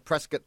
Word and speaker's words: Prescott: From Prescott, like rhymes with biscuit.